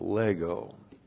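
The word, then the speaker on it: lego (λέγω): L-E-G, long O, lego.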